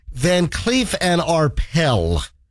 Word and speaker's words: Van Cleef & Arpels: Van Cleef and Arpels. The S at the end is silent.